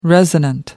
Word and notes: resonant: American English

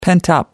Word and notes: pent up: American English

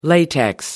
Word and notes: latex: American English

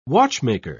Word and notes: watchmaker: British English

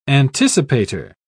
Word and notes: anticipator: British English

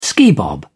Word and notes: skibob: British English

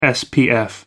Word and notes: spf: American English

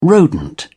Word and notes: rodent: British English

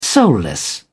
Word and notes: soulless: British English

